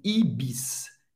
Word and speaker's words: Ibis: O S no final vai ter um som S mesmo. Ibis, Ibis, Ibis, hotel em francês, hotel, hotel.